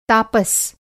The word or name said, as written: तापस